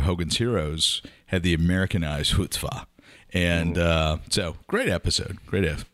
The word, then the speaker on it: chutzpah: So, Gladwell talked about Chumspa. Am I saying that right? – Chutzpah.